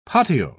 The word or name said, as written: patio